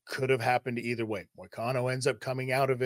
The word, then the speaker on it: Moicano: But Money Morricone, I guess, is that how you say his last name? – Morricone? Oh, Renato Moicano. Money Moicano. – That's what it is. Money Moicano. So I can't even say it when I know the pronunciation. – Moicano. – Moicano. I'm so Canadian.